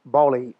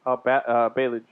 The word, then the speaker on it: Ballage: Ballage. I can't pronounce. I'm having trouble. – Baylage.